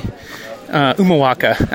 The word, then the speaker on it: Humahuaca: Humahuaca, actually, no H, don't pronounce that H.